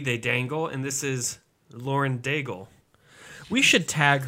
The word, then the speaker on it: Lauren Daigle: It is Lauren Daigle.